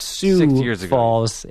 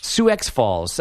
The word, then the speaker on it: Sioux Falls: It's pronounced Sioux Falls. It was. Thank God I remember. Sioux Falls.